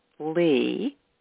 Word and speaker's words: Lee: I like my middle name, Lee, which is spelled L-E-E, because that was also my father's middle name.